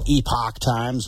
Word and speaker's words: Epoch Times: Uh, the Epic Times. That's how I pronounce it anyway. I've heard it pronounced Epoch Times, but I'm going to go with Epic Times.